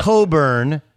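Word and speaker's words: Cockburn: Author, it's Oscar. Coburn. But it's a lot like Kofi Coburn. It's spelled C-O-C-K-B. – Oh, jeez. – B-U-R-N.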